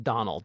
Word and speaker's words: Domhnall: It's spelled, let me see if I, it's an Irish name. D-O-M-H-N-A-L-L, I think. Yeah, but it's pronounced Domhnall.